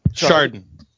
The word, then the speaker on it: Chardon: Ooh, I might have butchered that. – Chardon. – Chardon, Ohio. – Chardon. It's not far from where I grew up. It's more like an SH than a CH.